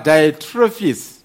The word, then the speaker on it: Diotrephes: I've written briefly to the church, but Diotrephes, that's the pronunciation.